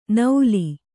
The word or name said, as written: ನೌಲಿ